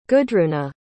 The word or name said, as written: gudruna